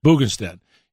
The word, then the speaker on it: Bundestag: When you see a Republican Party lockstep, like the Bundestag, how did you say that? In Germany, the Bundestag.